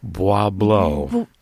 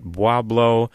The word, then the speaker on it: Bois Blanc: And we've got John and Elizabeth Kilborn out of, is that Boyce Blank Island? – Bois Blanc. Now, oh my gosh. – It could be, I don't remember what my first pronunciation was. I was corrected that some people go with Bois Blanc, some with Bois Blanc, not Boblo, which is down near Detroit, as many people remember, but Bois Blanc, Bois Blanc.